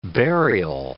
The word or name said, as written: burial